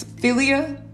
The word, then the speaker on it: philia: It's philia. Philia. So it's spelled P-H-I-L-I-A.